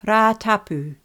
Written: Ratapu

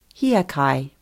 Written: hiakai

